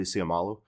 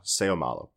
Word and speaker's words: Seumalo: Did I say that correctly? Seumalo. Like Junior Seau. Seumalo. Got it.